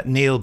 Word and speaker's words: Neil: I think his name's pronounced Neil, but it's spelt with the E and the I around the other way, so it could be Nile. So sorry, Neil or Nile.